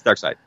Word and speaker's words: Darkseid: Seed or Side? I thought it was Darkseid. – It's Darkseid. – Okay. – It's spelled Seed, but it's pronounced Side.